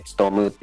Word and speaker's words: 農: えっと、ちょっと珍しい漢字でして、つとむのっていう漢字は、えっと、農業の農でつとむって読みます。